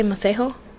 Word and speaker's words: Masego: It was Masego, the saxophone dude. Uh-huh. – Masego. – Masego. Is this spelled? – Masego. – You say Masego? – I mean, that's how he pronounced it.